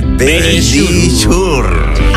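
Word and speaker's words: Verisure: Sí, pero dilo tú que pronuncias mejor. – Sí, venga, dilo. – Nuestros amigos de Bellisur. – Ahí lo habéis dicho divinamente.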